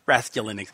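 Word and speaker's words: Raskulinecz: Here's the correct pronunciation. – Nick. It's just Nick. – Raskolunik. – Raskolunik. Yes.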